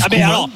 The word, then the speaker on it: Koeman: Okay, dans ce cas-là, Koeman, faut qu'on dise Koeman aussi. – On dit Koeman, bien sûr.